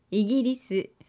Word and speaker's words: イギリス: イギリス.